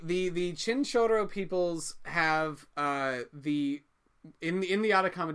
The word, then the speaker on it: Chinchorro: In the Atacama Desert, where conditions are perfect for mummifying, there was several thousand years BCE, there was the Chinchorro people. – The Choro people. – Chinchorro. I can't roll my R's, but normally my Spanish pronunciation is a lot better than that.